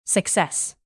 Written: success

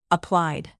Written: applied

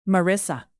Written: marissa

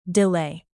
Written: delay

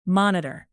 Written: monitor

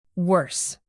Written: worse

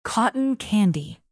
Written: cotton candy

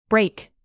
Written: brake

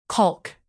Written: caulk

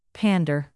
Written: pander

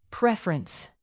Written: preference

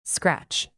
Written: scratch

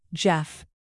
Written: geoff

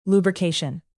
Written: lubrication

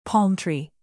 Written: palm-tree

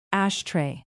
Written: ashtray

